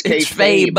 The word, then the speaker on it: kayfabe: It's you keep saying it wrong, Durs. It's kayfabe. – Fabe. – With a B.